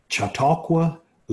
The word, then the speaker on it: Chautauqua: Nearby, Chautauqua Lake is a freshwater lake used by fishermen.